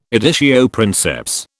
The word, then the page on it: editio princeps: British English